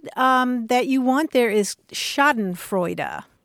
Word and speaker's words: Schadenfreude: It's S-C-H-A-D-E-N-F-R-E-U-D-E. Schadenfreude. Yeah. – Okay, so we wrote the word, but it's Schaden. – Yeah, Schaden. Mm-hmm. Yeah, it retains something of the German pronunciation. And that E on the end is pronounced as a U.